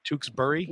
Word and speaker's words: Tewksbury: Did I say that right? Tewksbury? – Yes.